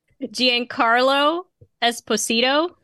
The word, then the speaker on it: Giancarlo Esposito: How do you say that name? Giannacerio what? – Giancarlo Esposito? – Yeah, you nailed it.